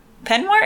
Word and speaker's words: peignoir: She doesn't even have her penoir. Is that how you say it? Yeah. She doesn't. Yeah, miss her pen peignoir.